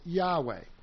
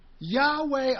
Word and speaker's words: Yahweh: So because the Jews haven't pronounced the name of God in centuries, actually probably since about the 3rd century, we're not 100% sure that the pronunciation is Yahweh. But we are 100% sure that it's not Jehovah, because that was a man-made pronunciation.